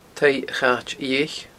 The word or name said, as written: taigh a chait fhiadhaich